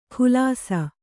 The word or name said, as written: ಖುಲಾಸ